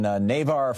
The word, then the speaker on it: Navarre: John in Navarre, Florida. Am I saying that right? N-A-V-A-R?